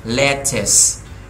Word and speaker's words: lettuce: Ở đây chúng ta không có dấu nhấn thì chúng ta phải đọc xuống. Lettuce, lettuce, lettuce.